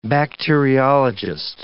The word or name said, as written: bacteriologist